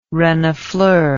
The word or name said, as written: renifleur